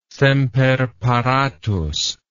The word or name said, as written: semper paratus